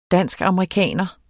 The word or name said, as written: danskamerikaner